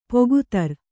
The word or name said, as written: ಪೊಗುತರ್